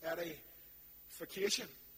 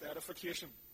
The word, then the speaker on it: edification: This is Eddie Vacation. Oh, edification. That's pronounced edification. By the way, before you think I'm common as muck, edification.